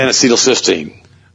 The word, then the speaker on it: N-acetylcysteine: N-acetylcysteine.